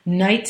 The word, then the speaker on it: Knight: Knight spelt with a K-N-I-G-H-T, Agard, A-G-A-R-D.